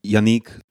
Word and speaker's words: Yannick: Can I just double check that I'm saying your name right? Is it Yannick or Yannick? – Yeah, Yannick, but Yannick. It's perfect. Don't worry.